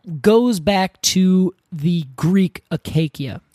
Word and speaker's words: acacia: But the funny thing is, these trees that literally come from the Greek word for thorn, and now we pronounce it as acacia, when it, back then I think because they did hard C's, would have been acacia.